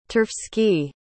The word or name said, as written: turfski